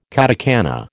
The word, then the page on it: katakana: American English